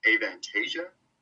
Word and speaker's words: Avantasia: Is it pronounced Avantasia? That singer and Bruce were part of with Tobias? – Yeah, that's a, that's a, good enough pronunciation for me.